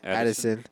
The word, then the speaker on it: Addison: And that's going to be Jordan Addison. Did I say that right? – Addison.